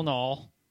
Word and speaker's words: Knoll: And as always, our executive producer, Jamie, the ball knoll. – Yeah. Knoll rhymes with ball. World, learn how to pronounce it.